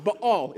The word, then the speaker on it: Baal: Baal is how you pronounce it in the original language.